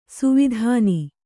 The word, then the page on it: ಸುವಿಧಾನ: kannada; suvidhāna